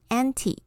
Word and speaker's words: anti: 前面这个部分是 a n t i，anti 是有反什么什么的意思，它可以念成 anti，也可以念成 anti，都可以。